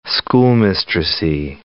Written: schoolmistressy

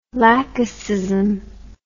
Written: laxism

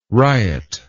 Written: ryot

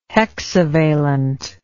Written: hexavalent

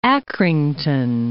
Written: Accrington

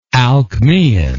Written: alcmaeon

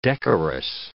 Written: decorous

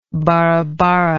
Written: barabara

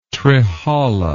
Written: trehala

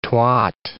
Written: twat

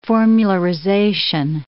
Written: formularization

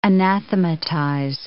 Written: anathematize

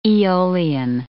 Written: aeolian

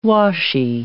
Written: washy

